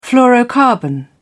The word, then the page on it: fluorocarbon: British English